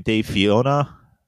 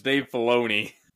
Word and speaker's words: Dave Filoni: I was not aware that Dave, how do you say his last name? Dave Fiona? – No, it's not the wife from Shrek. It's not Dave Fiona. It's Dave Filoni.